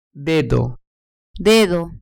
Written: dedo

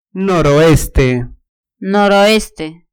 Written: noroeste

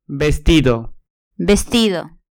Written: vestido

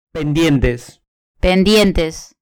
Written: pendientes

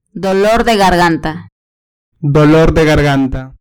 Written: dolor de garganta

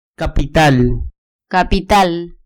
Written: capital

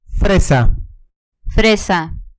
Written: fresa

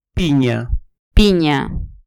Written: pinia

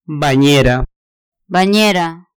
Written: baniera

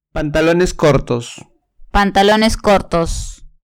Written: pantalones cortos

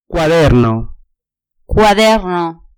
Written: cuaderno

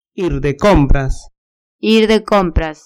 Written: ir de compras

